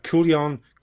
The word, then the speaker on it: Kujan: A customs agent by the name of Dave Kujan, that's another name as well, it's because it's spelled Kujan. – Yeah. – But it's like Kujan.